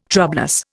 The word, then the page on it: troublous: Indian